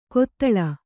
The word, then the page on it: ಕೊತ್ತಳ: kottaḷa